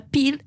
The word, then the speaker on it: Peel: Pell. Alors qu'elle joue Emma Peel avec deux E qui se prononcent I en anglais.